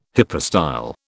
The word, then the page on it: hypostyle: British English